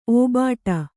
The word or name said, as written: ಒಬ್ಬೊಟ್ಟು